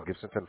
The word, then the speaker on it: Gibsonton: We are located out of Ohio, but our main warehouse is in Gibsonton, Florida. Right near Tampa. – What was the city? – Gibsonton.